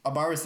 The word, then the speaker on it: Abaris: Yep, that's how you, it's A-B-A-R-I-S. That's how you pronounce, spell it, so Abaris.